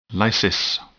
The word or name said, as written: lysis